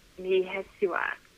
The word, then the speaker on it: Mihesuah: Her name is Devin Mihesua. It's her name. Her last name is spelled Mihesuah.